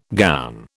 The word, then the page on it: gown: British English